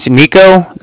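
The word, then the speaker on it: Niko: Hi, my name's Nico. That's N-I-K-O. Calling from the Bank of Cardiff, and that's spelled C-A-R-D-I-F-F, as in Frank.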